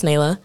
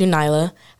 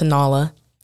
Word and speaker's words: Nayla: But yeah, it's pronounced Nayla. I also will respond to Nyla. I will not respond to Nala from like The Lion King, because people have done that, and it gets really annoying after a while, but it's fine.